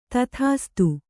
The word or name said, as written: ತಥಾಸ್ತು